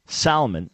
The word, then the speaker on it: Salmon P. Chase: Who is Salmon, spelled S-A-L-M-O-N?